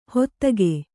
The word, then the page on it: ಹೊತ್ತಗೆ: kannada